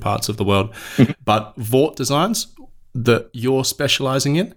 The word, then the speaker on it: VAWT: I've been doing a lot of reading on Vought technology and Hort technology, but how do I pronounce it? Is it acceptable for me to say Vought and Hort, or do I need to use it as the acronym and say H-A-W-T or V-A-W-T? – No, you pronounce it Vought or Hort.